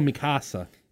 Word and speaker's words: Mikasa: My- That's how they pronounce it in the show, Mikasa.